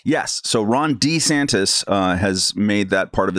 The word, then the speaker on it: DeSantis: I think we got to adjust our pronunciation. It really is DeSantis, according to him, I think.